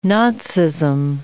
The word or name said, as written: Nazism